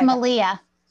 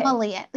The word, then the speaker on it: Malia: It's Malia. – Yeah, okay. – Malia. Yeah, like Maria, but with an L.